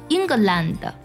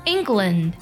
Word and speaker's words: England: 下个英格兰，那不少同学读成 England，其实这里呢 LAND 它读作 land，字母 A 呢发的是 a 的音， England。